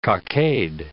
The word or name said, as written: cockade